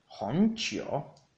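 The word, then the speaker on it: honchio (红酒): Vino tinto en chino se dice honchio, honchio, honchio, con un segundo tono en la O de hon y un tercer tono en la, en la U de chio, honchio.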